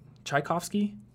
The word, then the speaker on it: Tchaikovsky: Is that how you pronounce Tchaikovsky? – That seems right enough to me. – I think so. – Seems right to my limited knowledge.